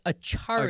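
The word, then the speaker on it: Acciari: Noel Akari, shot blocker extraordinaire. – I believe it's pronounced Achari.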